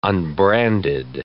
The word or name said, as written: unbranded